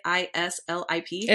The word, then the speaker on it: Islip: I don't know how to say it. Islip. – Islip, yeah. – Islip? – Yep.